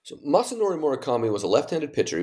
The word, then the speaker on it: Masanori Murakami: And another person in the book is, I hope I pronounce this right, Masanori Murakami.